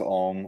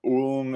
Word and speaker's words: Ulm: My last name is Ong or Ong or Ulm if you're in Germany, but for me it's Ong.